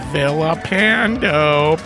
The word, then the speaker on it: Villapando: There's Vincent Villapando, pronounced with the L's, making an L sound, not a Y sound.